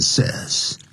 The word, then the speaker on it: says: 第三人称单数现在时 say 这个字加了 s 就要念成 says.